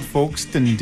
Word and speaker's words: Folkestone: It's called Folkestone, dude. – What an idiot. Like Edinburgh, right? – I didn't know that. It's spelled folks.